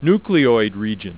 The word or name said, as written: nucleoid region